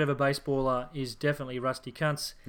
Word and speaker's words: Kuntz: Now, if you are familiar with the German way of speaking, you would actually pronounce his name Kunz.